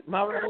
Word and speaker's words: Mariota: Is that how you say his name, Mariota? – Oh, Mariota for the Titans. – Mariota. Yeah.